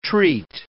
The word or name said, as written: treat